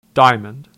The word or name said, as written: Diamond